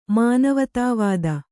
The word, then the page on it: ಮಾನವತಾವಾದಿ: mānavatāvādi